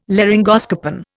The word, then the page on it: laryngoscopen: Indian